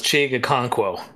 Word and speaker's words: Okonkwo: Um, you know, I, am feeling like uh, I'm as tall as Chig Okonkwo.